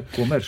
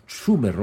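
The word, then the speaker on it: Cömert: Que no se pronuncia Comert, sino Sumer o así.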